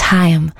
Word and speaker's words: Haim: The word is spelled, the name is spelled H-A-I-M, but it's not Haim. I'm just gonna set you correct. It's Hyam.